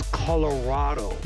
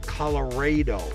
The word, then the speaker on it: Colorado: Also richtig ist Colorado, aber ich will ständig Colorado sagen. Ist mir gerade schon wieder so rausgerutscht einmal. Ich habe mich dann zwar korrigiert, aber das war schon, das reicht ihm schon. So spricht man es richtig aus. Moment. – It's Colorado. – Ja? Yeah? – Not Colorado.